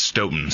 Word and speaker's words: Stoughton: And am I pronouncing this right? Because I've seen on the map many times we're driving through Stoughton. Is that how you say it? Or? – It's pronounced Stoughton. – Stoughton. So yeah. It's Stoughton. Okay.